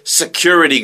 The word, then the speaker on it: security: 우리가 경비원을 얘기하는데 그래서 S-E-C-U-R-I-T-Y는 발음이요. Q의 액센트가 있기 때문에 리 발음이 강하게 안 나요. 그러니까 security 발음을 하기 오히려 힘들어요. 그래서 Q를 강조하기 때문에 리가 러가 되는 거예요.